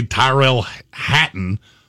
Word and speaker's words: Tyrell Hatton: I don't know why I've said, I've heard Brian call him Tyler Hatton for so long that now I can't say his name Tyrell. – Right. Tyrell Hatton.